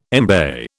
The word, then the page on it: embay: British English